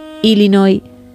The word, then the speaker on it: Illinois: Él, español, ella estadounidense, concretamente de Illinois, y fue quien grabó este audio para que escuchemos cómo se dice realmente.